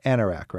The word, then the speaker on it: anorak: Now, sometimes somebody, it's bad weather out, and they want to put on their anorak, right? – Yeah. – Am I pronouncing that right? Anorak. – You're pronouncing that exactly right.